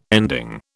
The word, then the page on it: ending: British English